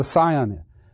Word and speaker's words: scion: You know what a scion is? S-C-I-O-N. A lot of times you look at it and say, oh, that's scion. But it's not. It's scion. It has a silent C, just like in scissors and the tribal group, the Scythians.